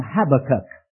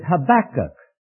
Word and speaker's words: Habakkuk: I have been impressed by some with whom I am impressed that the emphasis is on the last syllable, not the second. It doesn't rhyme with tobacco, Habakkuk. It was impressed upon me that it is Habakkuk or Habakkuk.